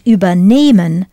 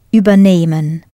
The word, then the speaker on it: übernehmen: Listen to where I put the stress and tell me whether unternehmen and übernehmen are separable verbs or not. Unternehmen, übernehmen. As the stress is not on the first syllable, the first part, they do not separate.